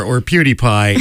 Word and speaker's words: PewDiePie: That's not how it's pronounced. – PewDiePie? PewDiePie? – That is how it is pronounced, yes. PewDiePie.